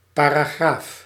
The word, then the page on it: section: /ˈsɛk.ʃən/